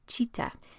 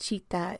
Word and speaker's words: citta: And I want to acknowledge that citta is a Sanskrit word. My pronunciation of it is very westernized, but if you want to look more into that and research more into that, it's spelled C-I-T-T-A.